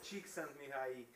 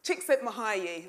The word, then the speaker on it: Csikszentmihalyi: Mihaly Csikszentmihalyi, I think that's how you say it. My partner's Hungarian, he was teaching me. Okay, what was it? – Csikszentmihalyi. – Csikszentmihalyi, that's it, there you go.